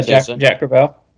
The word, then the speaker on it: Jack Crevalle: Have I pronounced that correctly? Jack Crevalle? – Yeah, Jack Crevalle.